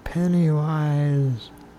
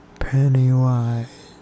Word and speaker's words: Pennywise: Pennywise. – I didn't hear that. You're going to have to slow it down a little bit and speak a little louder. – Pennywise.